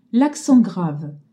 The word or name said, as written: grave